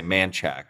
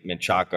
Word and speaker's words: Manchaca: So everyone says in Austin, that's how you know if someone's from Austin or not from there, because they'll pronounce it wrong if you're from Manchac. – There. They'll say Manchac. If they're not from there, they'll say Menchaca or something, or Manchaca or something along those lines. – Right. – And you're like, you know they're not from there because they tried to pronounce it correctly.